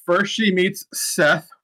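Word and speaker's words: Ceth: Yeah, it is, it's C-E-T-H, Seth with a C.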